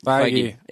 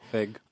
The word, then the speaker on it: Feige: And if Fox ran it a bit more like Marvel did and had a guy like Kevin Feige... – Feige. – Yeah, I can never pronounce his name right. – Fig.